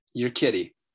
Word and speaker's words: Urquidy: Your kitty. Is how you pronounce Urquidy is how you pronounce, but you're kidding.